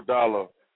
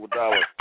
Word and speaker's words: Iguodala: how you pronounce his name? Iguodala? – Iguodala. – Iguodala. He's going to I used to call him Andre the Iguana, but yeah, but it's Iguodala.